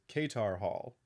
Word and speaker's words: Katar Hall: But Carter Hall and Shayera, there is going to be a difference in pronunciation between their alter ego. Specifically, also, I said that incorrectly. It's Katar Hall and Shayera are Thanagarian police officers who are flying to Earth to chase a criminal who has escaped Thanagarian justice.